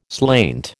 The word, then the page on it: slainte: American English